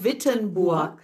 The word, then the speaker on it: Wittenburg: Also nicht Wittenberg, sondern, Wittenburg.